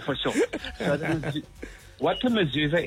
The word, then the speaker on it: mezuzah: So what I want to tell you is the mezuzah, you pronounce it correctly. Well done.